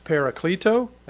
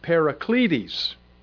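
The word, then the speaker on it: parakaleo: It's P-A-R-A-K-A-L-E-O. I've heard it pronounced Parakleto, and I've also heard it Paracletes. And even though that's not the way it's spelled, that's just the way that some from who read Greek have pronounced this very word. I don't know how you pronounce it. Through the years, I've always heard it pronounced as Parakleto. And the word means to call to one side, to call for, to summon.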